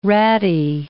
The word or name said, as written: ratty